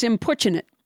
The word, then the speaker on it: importunate: I knew that word, but did not know how to pronounce it because I've only read it. I've never heard it said out loud. – It's importunate. – So I thought it was. – Importunate. – I would have thought that too, but it's importunate.